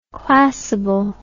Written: classable

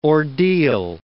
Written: ordeal

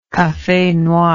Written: cafe noir

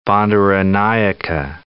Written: Bandaranaike

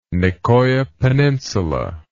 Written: Nicoya Peninsula

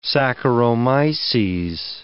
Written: saccharomyces

